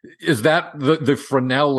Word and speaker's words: Fresnel: In the early 1800s, a Frenchman named Augustin Fresnel, the S is silent because he's French.